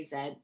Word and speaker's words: Z: We say, oh no, wait, in Canada, do you say Z or Zed? – I mean, technically we say Zed, but if you're talking about a movie or something, you would Yeah.